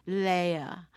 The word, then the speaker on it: laya: It's written L-A-Y-A, but it's pronounced, at least in my present teachers, Leia.